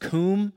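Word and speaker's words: kum (קוּם): The Hebrew word is, it's pronounced kum, and it literally means to become powerful or to endure.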